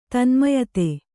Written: ತನ್ಮಯತೆ

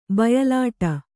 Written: ಬಯಲಾಟ

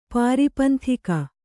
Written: ಪಾರಿಪಂಥಿಕ

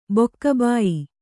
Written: ಬೊಕ್ಕಬಾಯಿ

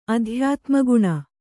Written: ಅಧ್ಯಾತ್ಮಗುಣ

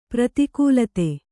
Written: ಪ್ರೋತ್ಕಲಿತ

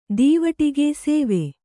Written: ದೀವಟಿಗೆ ಸೇವೆ

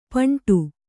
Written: ಪಿಂಡ

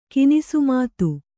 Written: ಕಿನಿಸುಮಾತು

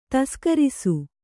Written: ತಸ್ಕರಿಸು

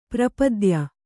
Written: ಪಾರಪತ್ಯ

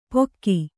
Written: ಪೊಕ್ಕಿ

